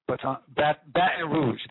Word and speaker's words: Baton Rouge: And last year, you know, last week, we were talking about he was in, I'm going to say it right, Baton Rouge, thank you.